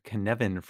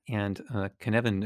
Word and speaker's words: Cynefin: It's also very hard to Google, so we should mention how to spell it, which is Cynefin, and that will be in our show notes, of course. It's Welsh, which reminds me that not to name my next nifty idea in Welsh.